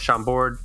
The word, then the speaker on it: Chambord: and 1 ounce of Chambord.